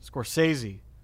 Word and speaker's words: Scorsese: Martin Scorsese? – Scorsese.